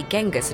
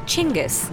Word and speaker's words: Chinggis: Firstly, his name isn't actually Genghis at all. It's pronounced Chinggis.